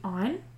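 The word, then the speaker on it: Inge: It was not reported to have spread to Scotland, but it did reach Ireland, where Lord Chancellor Hugh Eyne, is that how you pronounce that? – Yep.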